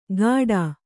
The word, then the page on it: ಗಾಡಾ: Kannada